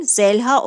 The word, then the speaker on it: Zeliha: Türkçe'de bu isim Zeliha yazılır, tamam mı kızım? Yüzüne dik dik baktığımı görünce Zeliha yazılır, Zelha okunur.